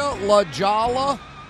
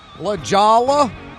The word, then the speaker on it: La Jolla: La Jolla. La Jolla.